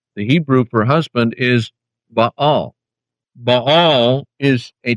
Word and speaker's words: Baal: If Baal, B-A-A-L, pronounced in Hebrew, Baal, if that word of and by itself were inherently evil, would God use it in reference to himself?